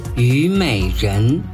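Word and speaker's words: Юмэйжэн (虞美人): Юмэйжэн. – Ю – вторым тоном, май – третьим тоном, жан – вторым тоном. Вместе получается Юмэйжэн – мак.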